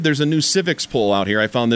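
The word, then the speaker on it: Civiqs: In Colorado here, Biden's approval rating, according to civics, that's spelled with a Q, C-I-V-I-Q-S, you can look this up yourself, is dead even 46-46, and we know what a blue state we live in here.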